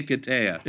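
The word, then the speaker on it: Hiketeia: And your Wonder Woman run, I mean, beginning with, I'm going to mangle the pronunciation of this, so it's the Hiketeria? – The Hiketeria. – Yeah, okay, I was close.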